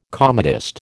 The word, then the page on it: comedist: American English